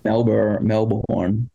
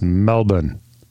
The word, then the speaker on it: Melbourne: Melbourne. – Well, it's spelled Melbourne, but I know it's Melbourne.